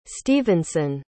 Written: stevenson

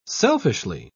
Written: selfishly

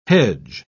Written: hedge

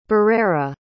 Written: barrera

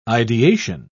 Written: ideation

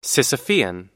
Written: sisyphean

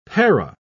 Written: para

